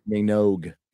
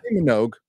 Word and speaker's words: Minogue: Sorry, I never pronounce her name right. Tell me, Kylie Minogue. – Minogue. I just wasn't sure if it was right.